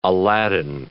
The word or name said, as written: Aladdin